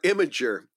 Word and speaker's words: Imgur: First of all, You pronounced I-M-G-U-R as imager? Is that how you pronounce it? – There's a big debate on the site. It's either Imgur or imager.